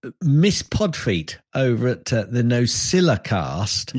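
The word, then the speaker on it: NosillaCast: And if you're listening, Alison, you'll hear that I said that correctly because so many people go Nocilla cast, a little bit like Streisand. Well, it hasn't got a Z in it, folks, and that's what she's complaining about.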